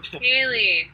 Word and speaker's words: Hailey: Hailey, it's just my name. At Hailey Giles, H-A-I-L-E-Y. It's like the precipitation plus an E-Y, so H-A-I-L-E-Y. – Okay. – G-I-L-E-S.